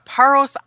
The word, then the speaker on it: Paros: And she's a resident of Paros Island. Did I say that right, Gail? – You did.